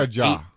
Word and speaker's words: Ja: Is it Jay or Ja? – I think it's Ja. – Ja. – I think. Okay. But I'm wrong on most name pronunciations. – And then it sounds like Ja by some people, but it's Ja.